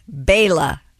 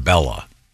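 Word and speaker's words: Béla: Okay, my buddy who's Hungarian says it's Bayla. B-A-Y-L-A is how it's pronounced. – And as in Bela Fleck. – Yes. – This guy says it's like Bella.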